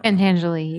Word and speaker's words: Pentangeli: was when Frank Pantangelo was or Pentangeli, how do you say his name? I can't. – Pentangeli, remember.